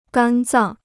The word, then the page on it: 肝脏: gān zàng